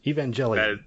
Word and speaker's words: Evangelion: Now I can't remember how it's actually pronounced. Evangelion? I believe is how it's pronounced. Evangelion. – That sounds correct.